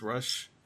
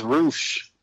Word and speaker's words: Rush: It's Roosh. – Roosh. – No, for real. That's how they pronounce his name. Is Roosh. I thought it was Rush for a long time, but I say Roosh.